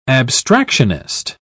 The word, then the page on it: abstractionist: British English